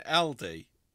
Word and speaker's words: Aldi: Most people tend to say Aldi like Aldi. I don't say Aldi. Effectively dragging out Aldi. The A syllable. But according to the retailer itself, we should be saying Aldi, which is what I say.